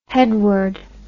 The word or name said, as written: headward